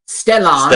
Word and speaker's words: Stellan: S-T-E-L-L-A-N. Stellan. – Stellan. All right. – S-K-A-R-S-G-A-R-D.